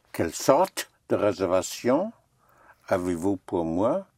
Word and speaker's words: sorte: Quelle sorte? – Right.